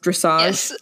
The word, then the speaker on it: dressage: is it pronounced dressage? – Yes.